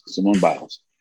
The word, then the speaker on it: Simone Biles: Simone Biles.